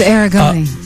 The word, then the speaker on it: Aragoni: Okay, give me a pronunciation on that. – It's Aragoni. – Aragoni? – Yeah, right, yeah. – Okay, put a long vowel, O vowel sound there.